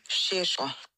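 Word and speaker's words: Šeško: Auch das ist nicht korrekt. – So? Es hat sich nämlich bei mir gemeldet und ähm, sie hat gesagt, dass der Name komplett falsch ausgesprochen wurde. Sie ist halb Slowenin und sie würde mir jetzt gerne mal eine Sprachnachricht schicken mit der richtigen Aussprache. Dann sind wir auch alle schlauer. So, aufgepasst? Benjamin Shisho.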